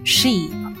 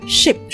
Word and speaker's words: ship: 那么，ship 这个单词你要注意一下，就是不要读成了长元音 sheep 啊，因为长元音 sheep 是绵羊的意思，就是动物那个羊咩咩啊。那 ship 是短元音，轮船是一个短元音 i ship 啊，所以读的时候快速一点，the ship。